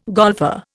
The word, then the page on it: golfer: Indian